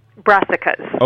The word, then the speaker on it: Brassicas: Brassicas.